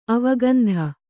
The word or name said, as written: ಅವಗಂಧ